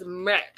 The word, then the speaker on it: Mert: So I kept calling him Matt, but his actual name is Mert. – So the way she pronounces it. – The way she pronounces it, yeah. Like, even in my notes, I had to scratch it out because she says Matt.